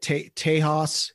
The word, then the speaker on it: Tejas: After the worldwide Texas tour, and that was the, how would you say it? Tejas. It's like the Tejas.